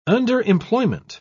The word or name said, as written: underemployment